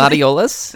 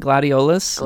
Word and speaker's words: Gladiolus: I still can't pronounce Gladi's name properly. – Gladiolus. – That's it. – Is that what it is? – Something like that. There you go. – Prompto, Ignis, and Gladiolus.